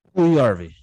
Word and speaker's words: Puljujarvi: Puljujarvi.